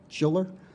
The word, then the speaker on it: Schiller: I go by the name of Randy Schiller, but my legal name is Randolph Schiller, R-A-N-D-O-L-P-H-S-C-H-I-L-L-E-R.